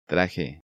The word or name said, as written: Traje